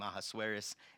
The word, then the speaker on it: Ahasuerus: So we'll pronounce his name Ahasuerus, and I need a place to sleep tonight.